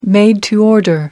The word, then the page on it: made to order: British English